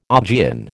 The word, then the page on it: Augean: American English